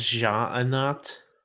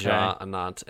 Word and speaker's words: Ja'anat: Yes, I believe it was Ja'anat. – It is okay. Ja'anat, and good luck, Paul, because there are silent letters here.